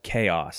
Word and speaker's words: KEOS: It was called like, the morning announcements were called Chaos. So I went to Austin High School. – Yeah. – So it was K-E-O-S. AHS. They pronounced it chaos. – Chaos.